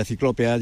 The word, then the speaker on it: ciclópea: Y, por ejemplo, yo ahora mismo estoy al lado, literalmente al lado de lo que fue la muralla de la ciudad, muralla ciclópeda. Creo que lo he dicho bien, no lo sé. – Sí, es la muralla ciclópea ya del siglo IV,